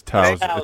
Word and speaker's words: Towson: Did you spell that right? Is it Towson? Is it Townsend? – Yeah. It's Towson. It's Towson. It's in Maryland.